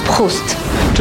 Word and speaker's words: Proust: It's pronounced Proust.